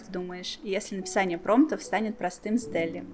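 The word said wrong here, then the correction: DALL-E: Дали, как он правильно пишется? Читается. – Дали, Дали, Дали, Дали.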